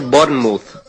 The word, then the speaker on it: Bournemouth: Se dice Bournemouth. Bournemouth.